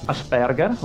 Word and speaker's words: Asperger: Asperger, ok.